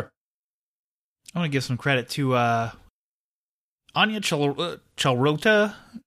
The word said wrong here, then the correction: Chalotra: Chalotra, sure. I'm sorry I butchered your name if you ever listened to the show.